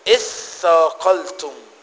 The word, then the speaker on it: اثاقلتم: يا أيها الذين آمنوا ما لكم إذا قيل لكم انفروا في سبيل الله اثاقلتم إلى الأرض، ولا اثاقلتم، في تاء ولا ما فيش تاء؟ مفيش تاء. اثقلت، انطق الكلمه كده، الثاء عليها تشديد، يبقى تقول ايه؟ اثقلتم. مفيش، مفيش، يبقى ثاء مشدده.